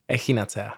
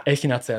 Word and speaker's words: Echinacea: Jak sa to správne vyslovuje? – Echinacea. – Echinacea, takto, hej.